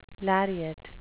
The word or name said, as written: lariat